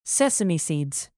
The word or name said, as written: sesame seeds